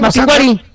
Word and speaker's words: Mapinguari: No, es que no tiene acento en la I. – No tiene acento en la I. – Mapinguari.